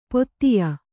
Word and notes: ಪೊತ್ತಿಯ: pottiya

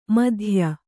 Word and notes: ಮಿಥ್ಯ: Kannada

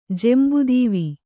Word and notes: ಜೆಂಬುದೀವಿ: kannada

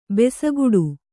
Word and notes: ಭುಸುಗುಡು: bhusuguḍu; kannada

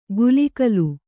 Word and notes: ಗುಲಿಕಲು: gulikalu

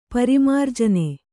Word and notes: ಪರಿಮಾರ್ಜನ: kannada

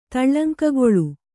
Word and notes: ತಳ್ಳಂಕಗೊಳ್ಳು: taḷḷaŋkagoḷḷu; Kannada

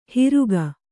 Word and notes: ಹಿರುಗ: hiruga

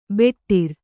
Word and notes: ಬಟಾರಿ: baṭāri; Kannada

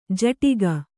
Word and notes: ಝಾಡಿಕೆ: Kannada

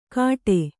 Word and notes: ಕಾಟೆ: kannada; kāṭe

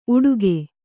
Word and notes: ಉಡುಗೆ: kannada

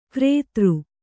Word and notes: ಕ್ರೇತೃ: krētř

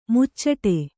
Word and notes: ಮುಚ್ಚಟೆ: muccaṭe

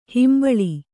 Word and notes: ಹಂಬಲು: Kannada

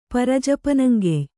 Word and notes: ಪರಜಪನಂಗೆಯ್: pari japanaŋgey

